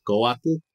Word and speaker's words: Coatl: It's pronounced Coatl in Nahuatl. And that's my, like, Hispanicized Nahuatl. Like, if we actually got an indigenous Nahuatl speaker, they probably say it differently, too. That TL sound tends to throw people off, but it's okay if you pronounce it as Coatl. With like almost like you're saying waddle, that's fine.